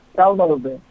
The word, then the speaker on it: Thalma Lobel: Yeah, Thalma Lobel.